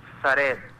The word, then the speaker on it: Zared: Con Mario Zared. – ¿Mario? – Zared. – ¿Cómo se escribe tu nombre? Discúlpame. – Z-A-R-E-D.